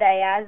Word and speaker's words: Ayaz: If you just type up my name, Huda Ayaz, or the book, Friesland New Beginning, then you'll find all the information online.